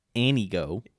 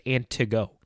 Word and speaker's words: Antigo: Yeah, and if you're from Anago, we'll pronounce it your way, Anigo. – Is it Anigo? Did they say Anigo? – Yeah. – There's a T in there. It's Antigo.